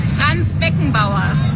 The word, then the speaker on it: Franz Beckenbauer: Franz Beckenbauer.